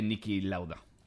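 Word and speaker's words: Niki Lauda: Y como interesante ha sido la vida de Nicky Lauda. ¿Eh? ¿Lo he dicho bien ahora? Ahora sí, ahora sí.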